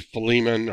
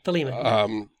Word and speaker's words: Philemon: And he rewrote the book of, I've heard it pronounced variously, Philemon or Philemon. Oh, yeah, Philemon.